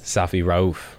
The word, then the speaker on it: Safi Rauf: Safi Rauf.